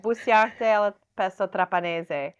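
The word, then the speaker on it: busiate al pesto trapanese: Then we have, gonna say this wrong, busante al pesto trapanese. – Yeah, okay. Busante al pesto trapanese. – Yes, perfect. Yeah.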